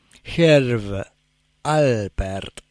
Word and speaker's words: Herb Alpert: Qué increíble, qué buena canción esa de recién, el tema del tercer hombre, y en esta versión además del, a ver si pronuncio bien, Herb Halpert and the Tijuana Brass. Herb Alpert, porque vi que es Herb con B larga, Alpert con P. Yo siempre lo digo al revés y ha sido causa acá de alguna corrección.